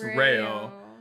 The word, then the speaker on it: Reo: I have to like stop myself from saying Ryo every time, but his name is R-E-O, pronounced Ryo.